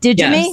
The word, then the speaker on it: Digi-Me: Is that how you pronounce it? Digimi? – Yes.